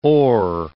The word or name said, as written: Orr